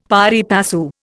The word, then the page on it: pari passu: Indian